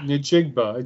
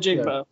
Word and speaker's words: Njigba: Njigba. Just the Njigba. Way it's spelled. – Njigba.